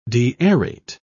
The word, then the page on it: deaerate: British English